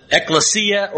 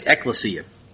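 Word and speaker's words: ekklesia (ἐκκλησία): either pronounce it ekklesia or ecclesia, but I think it's ekklesia.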